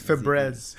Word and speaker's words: Febreze: It's Febreze.